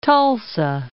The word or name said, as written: Tulsa